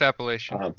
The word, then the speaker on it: Appalachian: And just kind of, now I'm in my head with that, whether I'm pronouncing it right, Appalachian. – It's just Appalachian.